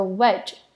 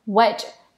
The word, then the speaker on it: wedge: 再我们要来看有一个跟它发音很像的字，叫做 wedge。 wedge 它的 e 呢只有发短音，e 的音，dg 发 j，wedge。